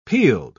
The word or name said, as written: peeled